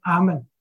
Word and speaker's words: 阿们: 主祷文最后的两个字是阿们，我们祷告的时候通常也是以阿们作为结束。这是什么意思呢？你说阿们，你不要觉得这是一个秘诀，这是一个咒语，你要明白什么意思。阿们这个字是音译字，在希伯来文、在希腊文里面都是读作阿们。